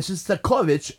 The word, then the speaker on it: Shostakovich: Shostakovich, as some people say. No, nobody says that. I say that. I'm Russian. I know how to say it, but it's Shostakovich. And Shostakovich wrote some great, great preludes and fugues.